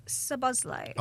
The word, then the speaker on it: Szoboszlai: Well, Dominic Szoboszlai.